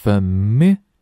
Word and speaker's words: familiar: Familiar, familiar. 要记得重读音阶啊，第二音阶重读。